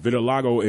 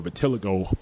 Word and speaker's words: vitiligo: And vitiligo is, or vitiligo, depending on how you pronounce it, is that Michael Jackson-esque mm-hmm.